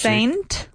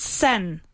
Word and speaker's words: saint: Saint? – When St. Patrick's... – Saint. How do you say santo? – Saint. – Not sen. Not son. It's saint.